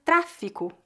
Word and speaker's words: tráfico: A palavra tráfico, com I e C, refere-se a um comércio ilegal e clandestino. Note que a pronúncia dessa palavra é feita com a vogal I no meio.